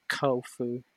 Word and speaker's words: Chofu: And, This is the Tamiya kit of the Kawasaki Ki-61 of the 244 Sentai, found at, is it pronounced Kofu? C-H-O-F-U? – Not sure. – I don't think it's a hard O sound. I think it's Kofu. – Like tofu, but better.